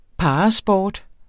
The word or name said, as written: parasport